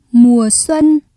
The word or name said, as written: mùa xuân